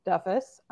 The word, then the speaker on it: Duffus: My name is Ashley Duffus. My last name is commonly mispronounced as Doofus, by the way it's spelled.